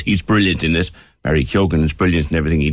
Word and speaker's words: Keoghan: It is Keoghan, apparently. He pronounces the G.